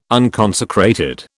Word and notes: unconsecrated: British English

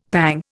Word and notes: pang: Indian

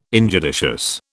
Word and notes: injudicious: British English

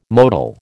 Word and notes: motile: American English

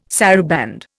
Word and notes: saraband: Indian

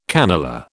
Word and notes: cannelure: British English

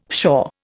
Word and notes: pshaw: Indian

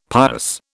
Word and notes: pious: British English

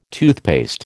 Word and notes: toothpaste: American English

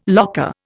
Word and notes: locker: Indian

